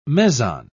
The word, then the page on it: meson: British English